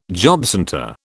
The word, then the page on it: jobcentre: British English